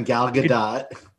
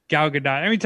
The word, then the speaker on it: Gal Gadot: You said it right the first time, Gal Gadot. – Gal Gadot.